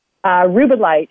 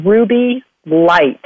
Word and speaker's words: Rubellite: Rubellite is not Ruby Light. So it's spelled R-U-B-E-L-L-I-T-E.